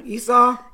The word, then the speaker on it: Esau: Specifically the story of Jacob and Esau. Am I saying that correctly? – Yeah, Esau.